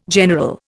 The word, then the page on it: general: Indian